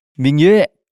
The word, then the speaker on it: Ming Yue: Welcome, Mingyue. Do I start talking now? – Yeah, can can can okay. can can can. Uh, my opening, my opening sentence will be it's actually pronounced Mingyu.